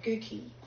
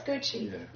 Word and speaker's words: Gucci: In the Italian, the C's are pronounced differently. If you've got two C's together, you're not talking about a pair of Gucci or Gucci shoes, are you? Not, or a handbag. – That's right, that's Gucci, yeah.